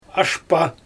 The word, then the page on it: ashpa: Xyzyl